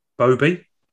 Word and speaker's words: boabie: B-O-A-B-I-E. – Bobby? – Yeah.